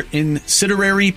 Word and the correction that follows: incendiary: Incinerary. Did I say that right? – Incendiary. – Incendiary.